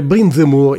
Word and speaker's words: Brindemur: Mentre il brano scorreva sono andato a informarmi e come al solito ho sbagliato clamorosamente la pronuncia, mi capita spesso. Si dovrebbe pronunciare Brindemur, il